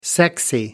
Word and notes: sexy: American English

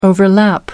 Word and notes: overlap: American English

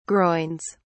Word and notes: groins: British English

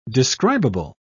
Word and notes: describable: British English

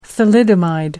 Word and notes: thalidomide: American English

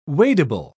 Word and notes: wadable: British English

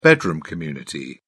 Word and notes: bedroom community: British English

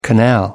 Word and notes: canal: American English

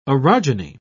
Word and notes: orogeny: British English

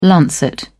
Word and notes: lancet: British English

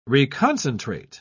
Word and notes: reconcentrate: British English

